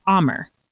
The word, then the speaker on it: Amer: It's A-M-E-R. Amer.